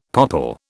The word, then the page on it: popple: British English